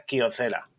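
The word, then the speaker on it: Kyocera: aquí por lo menos la pronunciación española es Kiocera, no Kiocera, ¿vale? – ¿Cómo?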